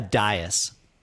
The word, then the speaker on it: dais: A dais. Dais. Is that possibly what you were thinking of? – Dais? – Is that how you pronounce it? – Yeah.